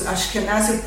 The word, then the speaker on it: Ashkenazi: Ashkenazi Jews, Ashkenazi pronounce again with a Z, not like the word Nazi,